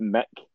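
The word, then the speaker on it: Muick: It's pronounced Mick. – Loch Mick? – Loch Mick, because that's what the Queen named one of her dogs. – Oh, aye, the dog. I remember seeing about the dog. – The dog's called Mick and that's how it's pronounced.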